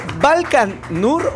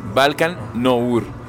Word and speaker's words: Balkan Nour: Se pronuncia con dos N's, Balkan Nour. Aunque se escribe con una N nada más, como si fuera Balkan Nur, ¿no? Algo así. Pero no, es Balkan Nour.